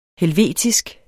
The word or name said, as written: helvetisk